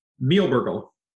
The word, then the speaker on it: Mielbergel: Mielbergel. – Meal burger, burgle. – Meal yep. burgle. Nice. You got to get the German and the French in there.